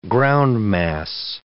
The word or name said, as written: groundmass